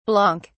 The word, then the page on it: blanc: British English